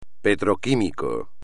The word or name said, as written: petroquímico